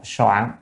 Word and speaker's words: soạn: À, Tân La Nguyên Hiểu chữ này á, thì thông thường chúng ta đọc là chữ trạch nha, nhưng mà ở đây đọc soạn, âm soạn mới đúng, soạn.